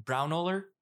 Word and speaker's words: Braunohler: Braunohler.